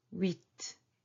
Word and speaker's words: huit: 7, S-E-P-T. The P is silent. 8 is 8, 8. Oui, oui, as when you say yes in French, you say oui. So it's the same sound, but then you add T, huit, huit.